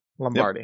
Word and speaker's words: Lombardy: We start off in Lombardy, Italy. Is that how you pronounce it? Lombardy. Yep. Thank you.